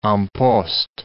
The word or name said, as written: en poste